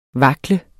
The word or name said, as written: vakle